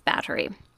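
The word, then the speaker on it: Báthory: Spelled Bathory, but I'm pretty sure it's Battery.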